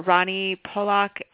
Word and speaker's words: Rani Polak: And it's by Dr. Rani Polak. Again, do you want to say your own name? – You pronounce it just perfect.